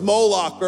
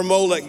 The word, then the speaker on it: Moloch: The first one was Moloch or Molech. You can pronounce it either way.